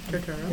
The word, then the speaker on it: Turturro: John Turturro. Turturro? – Turturro. – Yeah, I think I'm saying his name right.